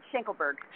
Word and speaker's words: Schenkelberg: That's close. It's a long name. It's Schenkelberg.